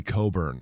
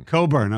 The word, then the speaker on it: Cockburn: It's spelled, it's not C-O, what, how would you spell Coburn? – C-O-B-U-R-N. – C-O-E, yeah. Yeah, no, it's a C-O-C-K. – Yes. – Yes, which is not normally pronounced as Co.